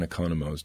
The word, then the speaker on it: Economo: And unfortunately, von Economo's disease was one of them. However, it was taught to me. – Was von Economo's, did I say that right? – Economo, sure.